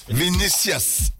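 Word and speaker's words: Vinícius: Vinicius Junior.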